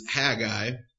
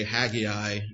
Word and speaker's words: Haggai: The first is Haggai, or you might say Haggai, either pronunciation is okay.